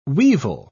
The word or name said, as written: weevil